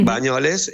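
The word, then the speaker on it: Banyoles: Sí, nosotros arrancamos en Bañolas, corríjame ustedes por ahí, pronuncio mal, creo que es Cataluña. – Bañolas, sí. – Bañoles, después estuvimos en Cantabria, en tres municipios de Cantabria.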